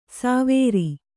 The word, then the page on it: ಸಾವೇರಿ: sāvēri; kannada